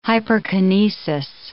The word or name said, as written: hyperkinesis